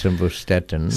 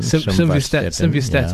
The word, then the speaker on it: simvastatin: Looking at this question, says Saddam, a female, 80 kilograms, diagnosed with fibromyalgia on Samuel Vustatin. Is that the correct pronunciation? – I don't know if it's Simvastatin. – Simvastatin, yeah. Yes.